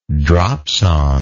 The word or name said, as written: dropsonde